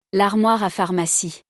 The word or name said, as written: L'armoire a pharmacie